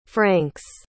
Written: francs

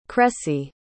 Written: cressy

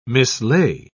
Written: mislay

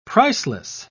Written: priceless

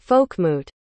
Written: folkmoot

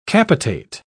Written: capitate

